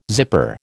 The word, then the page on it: zipper: American English